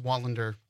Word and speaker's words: Wallander: Actually missed a syllable on my name. – I did? – It's Wallander.